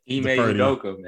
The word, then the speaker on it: Udoka: I'll Ime Udoka, man. – Udoka. You had Udoka. – You still didn't say it right.